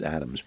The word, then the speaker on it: Adamvs: If you ever come across Adams, A-D-A-M-V-S, not U-S, they spell it with a V, it's Adams, but really good wine.